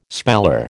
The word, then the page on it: speller: American English